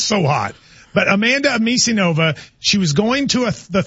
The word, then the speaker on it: Anisimova: Anisimova. – Anisimova. – How do you say it? – Anisimova. – How'd you say it, John? – Anisimova. – Anisimova.